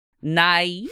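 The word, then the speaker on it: Na'ii'ees: Traditionally, all girls were required to complete the sunrise ceremony, also known as Nice. nice. That's how you say it.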